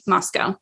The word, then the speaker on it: Moscow: I pronounced it in the American way, Moscow.